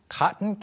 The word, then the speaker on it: cotton: 발음이 이제 우리가 그 면 또는 솜을 가리킬 때 코튼이라고 외래어처럼 표기하는 경우도 있는데 발음이 이제 cotton, T 발음이 안 날 때가 많죠. 특히 미국 영어에서.